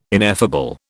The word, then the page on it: ineffable: British English